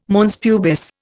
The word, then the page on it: mons pubis: Indian